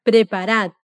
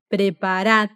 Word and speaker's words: preparad: Y ese preparad, cuando es una orden, y si está conjugado en vosotros, siempre termina escrito con D, pero a la hora de pronunciarlo no la leemos. Repite así, preparad.